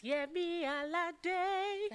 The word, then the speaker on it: Yemi Alade: I want you to say it how you say it in the songs. It's Yemi Alade.